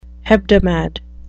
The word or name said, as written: hebdomad